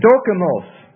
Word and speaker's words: dokimos: The Greek word here for approved is dokimos.